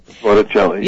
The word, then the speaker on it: Botticelli: I like how you were talking about the Botticelli, I think I'm saying that right. – Botticelli, yeah.